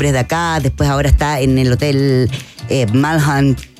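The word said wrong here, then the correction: Wyndham: nunca lo pronuncio bien. Windham.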